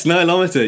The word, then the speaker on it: Nilometer: And I wonder if we can start with another artifact that you visited, the Nilometer. Am I pronouncing that correctly? – Yes, Nilometer. Yeah, I love the Nilometers.